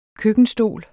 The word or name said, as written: køkkenstol